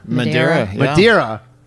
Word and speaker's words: Madeira: How do you say it? – Madeira. – Am I saying that right? – You just nailed it.